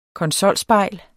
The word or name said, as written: konsolspejl